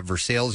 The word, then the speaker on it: Versailles: A college student from Versailles University, that's how they pronounce it in Kentucky, by the way, put her stir craziness to good use, a project that's going to help the deaf and hard of hearing community.